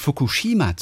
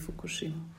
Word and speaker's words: Fukushima: Wir sind ja immer versucht, Fukushima zu sagen, was aber falsch ist, ne? – Ja, also es ist keine eindeutige Betonung auf der zweitletzten Silbe. – Es okay. ist eher so gleichförmig Fukushima.